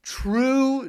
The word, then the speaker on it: True: If Chaz Paul and Troop Geordie hit me up. – Is it Troop? – True Geordie. – True. His name is True. How do you spell that? – T-R-U-E.